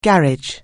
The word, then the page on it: garage: anglais